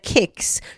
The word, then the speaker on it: kex: “Det heter kex”. Som en diss till alla göteborgare, alla 031 or som uttalar ja. Eh, eh, med, med sh istället för k.